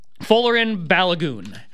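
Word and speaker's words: Folarin Balogun: Yeah, Follarin Balogun.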